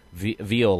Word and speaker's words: Veal: Veal, like I.E. Veal, you know, V-E-A-L.